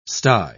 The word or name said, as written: sty